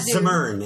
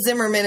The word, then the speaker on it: Zimmern: Oh, Andrew Zimmern. Yeah. – I always thought it was Zimmerman until I realized there was not a whole bunch of other Zimmerman.